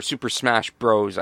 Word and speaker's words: Super Smash Bros: So the show started with Tarik versus, it was Stu Grayson from the Super Smash Brothers. Or Super Smash Bros, I guess. – Yeah. – Because I don't think they pronounce it as brothers.